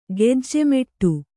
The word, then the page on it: ಗೆಜ್ಜೆಮೆಟ್ಟು: gejje meṭṭu; kannada